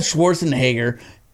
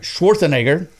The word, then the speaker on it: Schwarzenegger: Anyway, thinking about it, Arnold Schwarzenegger, I didn't say that right, Schwarzenegger, he plays good roles.